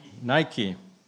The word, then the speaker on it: Nike: It sort of rhymes with the word spiky. Do you know it now? Nike.